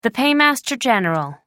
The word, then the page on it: paymaster general: American English